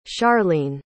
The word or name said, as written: scharlene